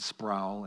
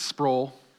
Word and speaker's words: Sproul: Sproul, I pronounced it wrong in the first service. I said Sproul, and I was severely reprimanded. It's Sproul.